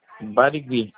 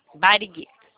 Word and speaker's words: Barigui: O bairro do senhor é Bagiri, né? – Barigui. – Barigui. Barigui, falei errado.